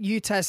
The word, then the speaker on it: UTAS: The Hawks and the Crows at UTAS Stadium on Sunday.